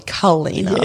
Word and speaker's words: Kalina: So like I like Billa Kalina when it's probably Kalina. – Yes. – So it's just a tiny little inflection.